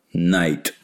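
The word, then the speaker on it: Knight: А поскольку «Night» оно пишется с буквой «K», но читается как «Night», как «Ночь». То есть, она путает «Night» in white satin и рыцарь в сверкающих доспехах.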